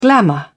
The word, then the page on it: glamor: British English